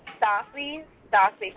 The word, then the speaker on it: Stassi: Yeah, the mixtape is hosted by, how do you pronounce her name? Stacia? – Her name is Stassi from the Bad Girls Club.